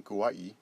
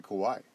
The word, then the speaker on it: Kauai: including Kauai, because I listened to Dylan Frost's podcast. I'd always said Kauai, but it's Kauai. I got to say it right.